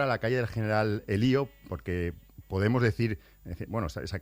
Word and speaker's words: Elío: Pero lo correcto sería General Elío, con acento en la I.